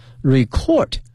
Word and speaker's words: record: R-E-C-O-R-D, 这个发音同学们一定要小心了， record 是动词表示录音哈，录制的意思，作为名词呢它的发音呢是 record, 可以指呢我们说记录或者说唱片等等。